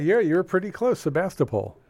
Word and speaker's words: Sebastopol: S-E-B-A-S-T-O-P-O-L.